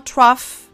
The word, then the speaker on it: trough: Y en el último, trough, si se fijan, suena como F nuevamente.